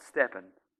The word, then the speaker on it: STPN: That's why we're starting this series called Stepping. And just so when the graphic is up on the screen so nobody freaks out, we are spelling it STPN, because we're cool. And it's pronounced stepping.